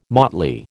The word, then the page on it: motley: American English